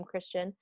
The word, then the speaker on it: Kristian: And that is her name, Christian, even though it's spelled like that. Spelled K-R-I-S-T-I-A-N.